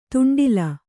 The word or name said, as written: ದಂಡ್ಲೆ